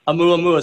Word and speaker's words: Oumuamua: I'm sure I'm going to mispronounce it, but it's Om uh, Uama Ua. Am I saying that at all correctly? I am not. It's probably more like Amu Amua. – Oumuamua.